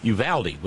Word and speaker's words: Uvalde: By the way, I've been mispronouncing it. It is, in fact, Uvalde. Joy in Ann Arbor, who is an expert in Texan, helped clear things up for me. Uvalde, with a short A, like Uvalde. Oh, see, I'm still screwing it up. Uvalde, with a short A, like in Valerie.